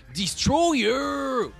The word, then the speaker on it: Destroyer: Le monumental Destroyer. Oui, ça se prononce comme ça.